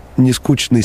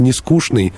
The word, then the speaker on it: Нескучный: Ну, так как заказчики жили в основном в Петербурге, то они, думаю, говорили «Нескучный» с ЧН, но мы, москвичи, будем говорить «Нескучный», поскольку у нас такая особенная особенность произношения.